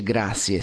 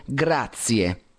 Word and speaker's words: grazie: Adesso grazie, non si dice grazie, si dice grazie, con Z, z, z.